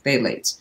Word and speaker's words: phthalates: It's spelled with a PH, but it's pronounced phthalates.